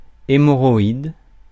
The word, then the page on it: pile: English